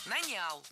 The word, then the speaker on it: нанял: Нанял. Неверно. Правильно, нанял. Ударение в этом слове падает на первый слог во всех формах прошедшего времени, кроме женского рода. Он нанял, оно наняло, они наняли, но она наняла.